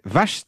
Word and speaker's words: Vacht: Et le dernier patronyme qu'on va étudier ce matin, c'est Vacht, c'est comme ça qu'on le prononce ? – C'est cela même.